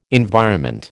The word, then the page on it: environment: American English